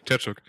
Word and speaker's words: Tkachuk: Der unsympathische Spieler des ganzen Turniers, Brady Tkachuk, wie wird er ausgesprochen? – Tkachuk.